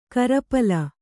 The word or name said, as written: ಕೋರುಪಾಲು